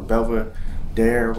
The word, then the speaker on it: Belvedere: Belvedere or Belvedere, whatever you pronounce it, it just sounded cool. So I said my new name is Ariel Belvedere.